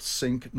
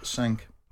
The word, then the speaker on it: Cinque: They became known as the Cinque Ports from the French word five, but always pronounced sink, not sank.